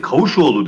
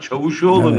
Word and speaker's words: Çavuşoğlu: Hayır, Kavuşoğlu dedi. Yani yanlış söyledi yani. – Tabii yanlış söyledi. Yani adamların gayri ciddiyetini anlatmak için söylüyorum. Daha Çavuşoğlu'nun, evet, adını telaffuz etmekten aciz Türkiye ile ilgili karar alıyor.